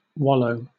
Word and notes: wallow: British English

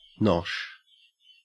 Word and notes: nosh: Australian English

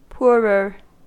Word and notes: poorer: American English

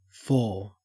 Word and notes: four: Australian English

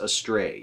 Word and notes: astray: American English

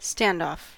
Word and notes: standoff: American English

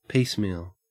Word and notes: piecemeal: Australian English

